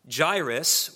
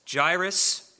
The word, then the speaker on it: Jairus: And behold, one of the rulers of the synagogue came, Jairus, or it could also be pronounced Jairus. Jairus is how I'm going to pronounce it.